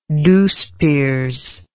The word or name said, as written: douceperes